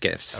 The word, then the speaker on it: GIF: What the correct pronunciation is? – Is GIFs. – Okay. – It's absolutely GIFs.